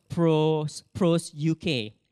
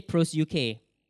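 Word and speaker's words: pros UK (προσευχή): If not, the English transliteration is the big text, and it's pronounced pros UK. Everybody say pros UK.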